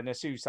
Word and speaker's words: Asus: An Asus Ally.